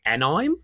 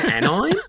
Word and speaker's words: anime: And I'd never heard it pronounced. I'd only ever seen it written down. So I was going, anime? You mean anime?